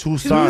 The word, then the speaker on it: Tucson: Tuxton, yeah. Wait, how do you guys say it? – It's Tucson. – Tucson, bro. – Tuxton. – The C is silent.